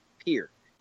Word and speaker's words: Pierre: It's pronounced like pier. It's not Pierre, but it's spelled Pierre. So I've been struggling with that. – It's not Pierre. Like the water. – Yeah, no, it's no, but it's almost it's spelled like that, but it's pronounced here.